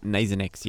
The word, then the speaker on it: Nasonex: On the front page there for $15.99, Nasonex, is that how you pronounce it? – Nasonex, yes.